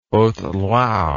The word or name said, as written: haute loire